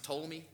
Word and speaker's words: Ptolemy: The Seleucid Empire of the north and the Ptolemy, or the, it's kind of pronounced Ptolemy, but I like to say Ptolemy to help you spell it.